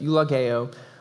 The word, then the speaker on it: eulogeo: There's a better way to say it, but it's eulogeo, which means to speak well of. It's literally the Greek word good. It's the E-U, the U sound.